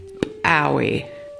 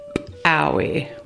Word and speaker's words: pawi: The ejective P sound in Klallam is pronounced just like the English letter P, but with a stronger pop. Here is the word twice more. P-owie. P-owie.